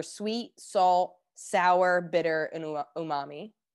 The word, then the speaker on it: umami: I didn't know about this until you told me about it, but the topic is, I'm going to say it wrong. Umami? – Umami. – Right? Umami? Umami. Okay. Yeah.